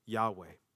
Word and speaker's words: Yahweh: Four letters in Hebrew, YHWH. We think it's pronounced as Yahweh.